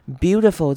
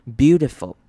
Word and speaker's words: beautiful: Beautiful 这个字呢，很多人要特别注意的是它的 I 的念法。 Beautiful 那个 I 也是一样念成短母音 I. Beauty 的声音 Beautiful. 然后呢，中间的 T 我们也会弱化变成像是 D 的声音 Beautiful.